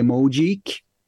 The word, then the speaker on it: Mojeek: It's spelled M-O-J-E-E-K. – No, I mean, how would you pronounce it? Sorry. – How you just said it. I remember I just told you. – Mojik.